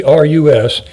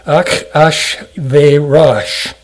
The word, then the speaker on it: Ahasuerus: Circumstances under the reign of King, and I'm not going to try to mispronounce this even, Ahasuerus, that's not the way it sounds in the Hebrew. I'll try to give you what the phonetic spelling is. Ach-ash-ve-rash.